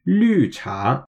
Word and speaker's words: 绿茶: It just means that if you're saying green, for example, like in the word for green tea, then you just have to pronounce it with a bit more of a curved mouth and get that kind of European style pronunciation in there. So it's pronounced 绿茶.